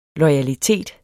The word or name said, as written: loyalitet